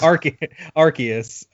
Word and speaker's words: Arceus: I was hoping for a pronunciation of that. Instead, you gave us Arceus. – Arceus.